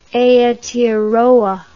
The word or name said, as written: Aoteoroa